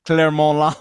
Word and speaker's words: Clermont: I think I even danced at the Claremont Lounge. Claremont, whatever it said. – That's the French pronunciation. Clermont Lounge.